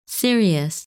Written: Sirius

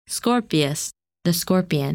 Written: Scorpius

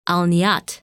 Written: Al Niyat

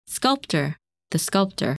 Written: Sculptor

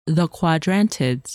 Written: Quadrantids

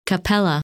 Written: Capella

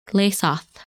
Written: Lesath

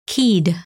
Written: Keid

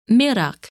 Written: Mirach

